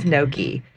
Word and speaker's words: Nochi: It is Noki. It is spelled like Nochi, N-O-C-H-I, but the board determined that we would pronounce it Enoki, and they said, well, we named it, we get to decide how you pronounce it, and it's pronounced Noki.